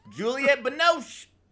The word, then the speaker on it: Juliette Binoche: Juliette Binoche.